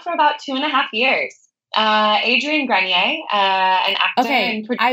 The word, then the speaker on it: Grenier: Grenier. – Yep. Grenier.